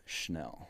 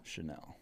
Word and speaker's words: Chenal: It's pronounced Chenal. – Chenal? – Chenal.